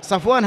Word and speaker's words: صفوان: أما الراوي صفوان بن عسال، صفوان هكذا كما تسمعون هذا نطقه، صفوان بصاد مفتوحة فاء ساكنة واو ألف نون، صفوان.